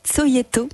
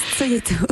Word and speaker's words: Cogito: Alors c'est Tsoyeto. – Ah, pardon. – Ça s'écrit Cogito, mais ça se prononce Tsoyeto.